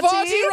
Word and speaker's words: Havarti: Havarti rhymes with 40.